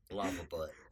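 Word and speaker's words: Lava Butte: That is not how you say it. – Lavaboot. – We are not in France. – Then fine, Lavaboot. – Yeah, there you go. Lavaboot.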